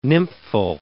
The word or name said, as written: nymphal